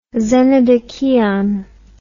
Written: xenodocheion

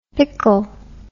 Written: pickel

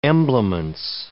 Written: emblements

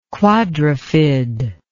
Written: quadrifid

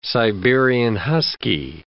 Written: Siberian husky

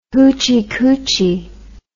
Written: hoochie coochie

